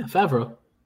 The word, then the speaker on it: Favreau: Favreau, right? – Yeah, Favreau.